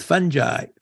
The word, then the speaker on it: fungi: Fascinating and also a little emotional for me due to my father's involvement in the pioneering work with fungi. Please work on your pronunciation. It's fungi. Okay.